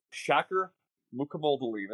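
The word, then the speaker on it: Shakir Mukhamadullin: So this is, I'll give you the correct pronunciation. I believe it's Shaker Mukamoldalin. That's how I believe he says it.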